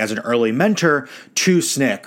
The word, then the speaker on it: SNCC: SNCC is how you pronounce that.